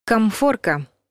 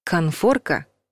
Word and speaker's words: конфорка: «Комфорка» — неправильный вариант. «Конфорка» — правильный вариант.